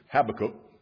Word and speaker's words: Habakkuk: Habakkuk chapter 2, or as some pronounce it, Habakkuk, chapter 2, verse 12, said, woe to him who builds a town with bloodshed, who establishes a city by iniquity.